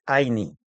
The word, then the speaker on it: Ayni: Ella me dijo: te voy a regalar una palabra quechua, que es Aini. Se escribe Ayni.